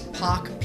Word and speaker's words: Pok: So it's like my name, Alex Pock, P-O-K.